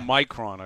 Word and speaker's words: Omicron: Omicron, I believe.